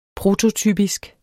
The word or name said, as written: prototypisk